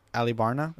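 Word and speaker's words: Alubarna: Alibarna. That's how it's pronounced. – Yeah. Well, that's how they said it in the dub.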